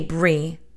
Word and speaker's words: Brigid: And I say Bri, but it's spelled B-R-I-G-I-D. So a lot of people pronounce it Brigid. So I don't want to confuse you, but I do call her Bri. That is the pronunciation that I've always known.